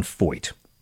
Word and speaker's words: Feucht: There's this guy, Sean Foyt. That's how it's pronounced, right? F-E-U-C-H-T.